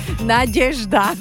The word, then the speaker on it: Nadežda: Inak pre Trnaváka ako repa, ako som ja, je to meno aj ťažko vysloviteľné, že dnes je tam meno Nadežďa, Nadežda, alebo... – Nadežda. – Nadežda. Dobre. – Že nevieš, kam máš dať mekčen, že? – No.